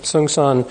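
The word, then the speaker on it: Seung Sahn: Sung San. Is that how you say his name, the Korean master? Sung San? How do you say that? Yeah, Sung San,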